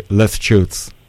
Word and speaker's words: Leches: Can you help me about how can I pronounce it correctly? Leches. – Leches. – Okay.